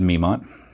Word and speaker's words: Memont: So it's David Memont. – David what? – David Memont. – How do you spell that? – M-E-M-O-N-T.